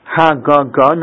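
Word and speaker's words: Hagaga (הגג): It's spelled hey gimel gimel. We pronounce it Hagaga. Normally there'd be another hey at the end of that word, but there isn't.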